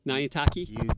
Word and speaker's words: Nyantakyi: Nyantaki? – You did good.